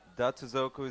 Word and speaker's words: Datsuzoku: Yeah, people do get confused with the pronunciation of that. So, yeah, just to give you guys a bit insight, Datsuzoku is Japanese,